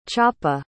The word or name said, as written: chapa